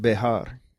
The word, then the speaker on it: Bexar: Well, it's pronounced it's supposed to be pronounced Bejar, because the X in Spanish is a J, which is an H, and the H is silent.